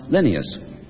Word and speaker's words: Linnaeus: Even the man who thought up the Latin names, Linnaeus, comes a cropper in Cambridge, Massachusetts, where the street named after him is pronounced Linnaeus.